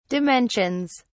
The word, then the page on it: dimensions: British English